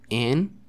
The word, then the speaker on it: an: Y se pronuncia, no se pronuncia an, sino que es como un, como si lo pronunciaras con una E al principio, como en, en, ¿sale?